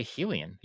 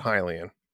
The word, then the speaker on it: Hylian: You say Hylian? – I have always said Hylian. – All right. I don't think I'm not going to insist I'm right. I'm just saying is that's how I pronounce it. – You're not right.